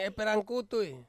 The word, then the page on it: perang kutui: Ansus